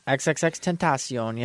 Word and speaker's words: XXXTentacion: Is that how you say it? I've always wondered how to say it. – XXXTentacion, yep. – Tentacion. – Okay.